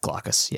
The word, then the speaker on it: Glaucus: So with this scene here, when, Glaucus, is that how you say his name? – I'd call it. Yeah. Glaucus. Yeah.